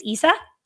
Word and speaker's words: Issa: So then we meet, I pronounce her name is Issa. – Yeah, sounds good.